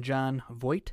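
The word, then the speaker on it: Jon Voight: And John Voight.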